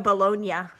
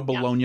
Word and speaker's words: Bologna: Joey Baloney. – Joey Bullshit, that's what we call him. But a Married with Children. – Actually, Joe Bologna. – Joe Bologna. Yeah. That's how my grandfather would pronounce it.